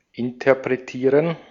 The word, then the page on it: maken: [maː.kǝː]